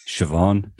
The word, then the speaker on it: Siobhan: Siobhan. Siobhan. – Rory, who's R-A-I-D-H at the end.